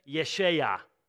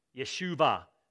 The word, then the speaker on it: Yeshua: Yeshua, Yeshuva, depending on how you want to pronounce that.